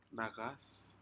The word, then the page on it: nacas: Aneityum